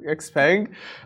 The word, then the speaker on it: Xpeng: Xpeng.